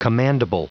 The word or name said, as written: commandable